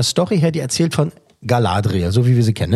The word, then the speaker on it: Galadriel: Ähm, Galadriel, hier wird so ausgesprochen, Galadriel.